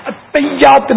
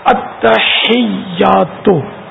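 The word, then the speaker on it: التحیات: یہ اتیات نہیں ہے, یہ بھی تلفظ بہت سو کو سیکھنے کی ضرورت ہے. اتحیات یا اتحیات, اتحیات, اتحیات,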